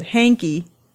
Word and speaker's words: Hengky: Henki Winjaja is, see, it's spelled Hengky, but you pronounce it Henki.